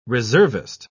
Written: reservist

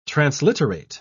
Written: transliterate

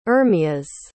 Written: Ermias

